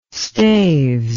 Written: staves

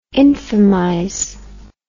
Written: infamize

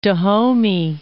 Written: Dahomey

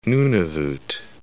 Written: Nunavut

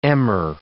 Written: emmer

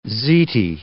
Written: ziti

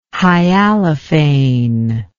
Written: hyalophane

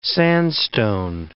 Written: sandstone

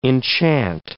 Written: enchant

